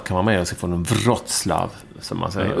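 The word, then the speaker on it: Wrocław: Uttalas det så? – Mm. Vad trodde du det var? – Wroclaw. – Mm. Ja, men det är det inte. Wroclaw. – Nej, okej. Jag har alltid sagt Wroclaw.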